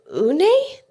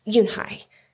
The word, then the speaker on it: Unhei: "Unhei?" the girl asked, scrunching up her face. "Ooh, ooh, Unhei," some kids chanted. "No, no," Yunhei corrected. "It's spelled U-N-H-E-I. It's pronounced Yunhei."